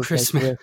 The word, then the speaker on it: Kreisman: So, Phil Kreisman, welcome to the New Books Network. – Thank you. I should have said this before. It's Kreisman, the first syllable. – Oh, Kreisman.